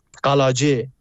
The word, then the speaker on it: قلاجه: یا روستاهای اطراف مریوان، چند تاش اگه اسم ببرم، روستای بالک، روستای نجمار، روستای دزلی، روستای بهرام آباد، قلاجه، تلفظ کردیشه، قلعجی فارسی می نویسن، و روستای اطراف بانه، همون باینجان، بلسن، آلود، اینا خب از روستاهایی بود که میتونم بگم بمباران شیمیایی شده بودن.